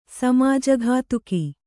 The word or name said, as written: ಸಮಾಜಘಾತುಕ